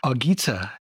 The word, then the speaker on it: Argita: And we have Detina and Argita.